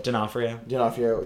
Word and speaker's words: D'Onofrio: I want Vincent D'Onofrio to be, or how do you say it? – D'Onofrio to be in a Spider-Man movie.